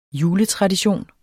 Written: juletradition

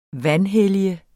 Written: vanhellige

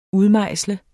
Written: udmejsle

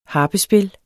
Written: harpespil

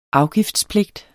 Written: afgiftspligt

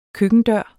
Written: køkkendør

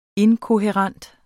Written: inkohærent

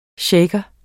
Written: shaker

